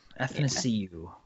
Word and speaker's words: Athanasiou: Athanasiou. Yeah. – Athanasiou. – I only know that because Bill calls him happy to see you.